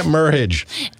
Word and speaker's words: Merhige: Spell that Merhige. – Merhige. Okay. I think you say Merhige the best I've heard it.